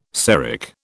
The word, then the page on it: Seric: British English